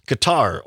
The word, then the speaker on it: Qatar: According to CNN and other sources, the United States and Qatar, or Qatar as some pronounce it,